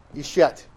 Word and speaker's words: Ishet: And the first word here that gets emphasized in the whole thing, it starts with Aleph, which is sort of the A of Hebrew. And it's a silent letter. And it has a little vowel mark under it that sounds like E. So you have Ishet.